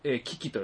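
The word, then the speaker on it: 記紀: それで、キキと読むそうです。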